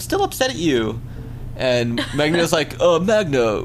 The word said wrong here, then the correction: Magda: we have to forget the past. – Magda. – How do you say it? – That's how you say it. – Can you spell it? – I think it's M-A-G-D-A. – Magda. Magnus and Magda.